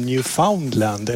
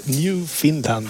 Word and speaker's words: Newfoundland: Och människor brukar ju uttala i Sverige Newfoundland som Newfoundland, eller hur? – Ja, just det. – Har du inte hört det? – Jo. – Men det korrekta är ju som Finland, Newfoundland.